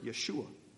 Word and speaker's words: Yeshua: Yeshua.